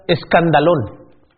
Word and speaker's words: escandalón: Esa palabra ofensa, en el original, se pronuncia escandalón.